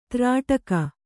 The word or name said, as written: ತ್ರಾಟಕ